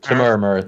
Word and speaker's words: Temuera: Temuera Morrison.